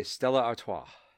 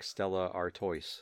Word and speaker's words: Stella Artois: And you have to say it like that. – Exactly. – Or else it's like Stella Artois. And then it doesn't taste as good.